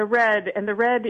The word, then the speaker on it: REDD: I know when we had met last spring at Food Tank, we talked about the project you're doing called, is it pronounced RED? – Yes, the RED. – R-E-D-D.